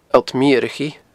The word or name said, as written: Allt Meurachaidh